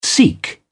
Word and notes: sikh: British English

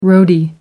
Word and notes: roadie: American English

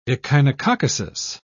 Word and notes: echinococcosis: British English

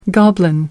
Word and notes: goblin: American English

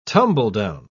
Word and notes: tumble-down: British English

